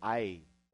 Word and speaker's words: Ai: Ai, sometimes pronounced in Hebrew, Ai.